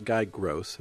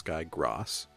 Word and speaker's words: Guy Gross: So it's not Guy Gross, it's Guy Gross. Gross. – Gross. – I don't know. It's spelled Grawss as a phonetic help.